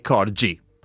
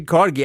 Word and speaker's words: corgi: Sulla questione della pronuncia di corgi e corgi, allora, in italiano è corgi. – Avevo ragione. – Sì, sì, sì. Oh, sì. In inglese è corgi.